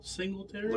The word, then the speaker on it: Singletary: Michael Singletary? – Look at Jonathan's comment.